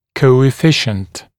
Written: coefficient

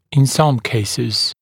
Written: in some cases

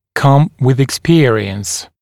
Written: come with experience

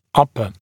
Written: upper

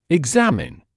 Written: examine

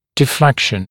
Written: deflection

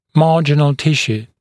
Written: marginal tissue

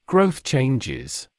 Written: growth changes

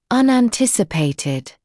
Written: unanticipated

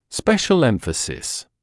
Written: special emphasis

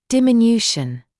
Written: diminution

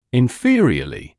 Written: inferiorly

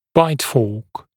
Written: bite fork

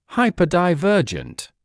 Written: hyperdivergent

